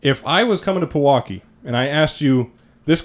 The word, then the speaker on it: Pewaukee: But anyways, before we get to basketball, you are a Pewaukee, Wisconsin native. Am I saying that right? – Pewaukee. Yeah. Yep. Pewaukee.